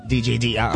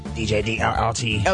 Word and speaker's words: Dirt: Periods, but DJ D-I-R-T. – Dirt. – No. – DJ... – D-I-R-T. – Is it? – DJ DRRT. – But it spells dirt. – DJ DRRT. – Okay. Anyway. – You have to say it like that.